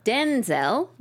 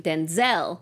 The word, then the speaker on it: Denzel: So apparently it's all about the emphasis. His dad says Denzel with the emphasis on the den, and then he pronounces his name Denzel with the emphasis on the zel.